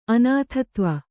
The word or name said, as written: ಅನಾಥತ್ವ